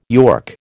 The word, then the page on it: york: American English